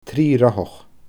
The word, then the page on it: trì-rothach: Scottish Gaelic